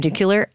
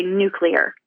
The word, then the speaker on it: nuclear: That said, most people, myself included, will say nuclear as opposed to nuclear.